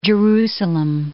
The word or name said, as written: Jerusalem